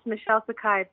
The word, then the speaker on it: Michelle Sakhai: Yes, Michelle Sakhai.